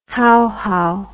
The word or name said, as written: Hauhau